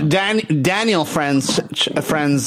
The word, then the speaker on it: Franzese: So Daniel Franzese, is that how you pronounce his name? – Yeah.